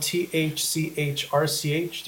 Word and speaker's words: thChrch: And it's spelled T H C H R C H. There's no vowels.